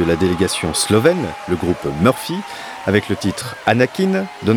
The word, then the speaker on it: Mrfy: c'est que Murphy, qui s'écrit M-R-F-Y, est un tout jeune groupe originaire de la ville de Novo Mesto, à l'est de la Slovénie.